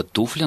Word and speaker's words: туфля: И слово «туфля» нужно произносить с ударением на первом слоге, и можно вспомнить в «Джентльменах удачи» обыграно двумя ошибками «чей туфля, мой туфля», здесь и рот нарушается, и ударение смещается.